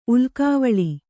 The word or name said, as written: ಉಲ್ಕಾವಳಿ